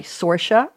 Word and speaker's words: Saoirse: And Josephine March, one of the sisters, played by Saoirse Ronan. I've seen her on enough interviews to know that's how she pronounces it. S-A-O-I-R-S-E, and she's from Ireland, I believe.